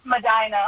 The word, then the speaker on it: Medina: If it's supposed to be pronounced Medina, I apologize. I never get those right. – Yes, it's pronounced Medina. – Nah! – Medina. – Yeah, like a normal person, but not a person from Ohio.